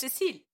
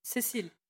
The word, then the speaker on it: Cécile: It's Cécile. Okay, I have to say my name. My name is Cécile, not Cécile.